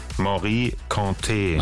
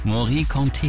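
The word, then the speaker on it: Mory Kanté: Wie wird's ausgesprochen? – Morikante. – Ach so, guck mal. Das war also falsch. Morikante, Jeke, Jeke.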